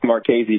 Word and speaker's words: Marchese: It's Marchese, but I don't fault you for it. – Okay, Marchese. Okay, the CH gives it a key, the K sound.